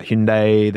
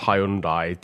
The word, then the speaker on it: Hyundai: I always thought it was pronounced Hyundai. Then I saw an advert the other day, and they said that it's pronounced Hyundai, and my mind was blown.